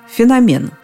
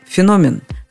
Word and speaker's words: феномен: Его любят вернуть мимоходом, украсив свою речь, но почему-то чаще оно звучит как «феномен». Между тем правильно будет «феномен».